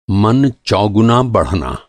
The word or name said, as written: चौगुना